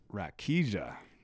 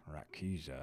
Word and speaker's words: Rakija: It was like Rakira. – Oh, we're going to do a pause and check. We'll be right back. Dang it. I said it wrong. It is Rakija. – Rakija.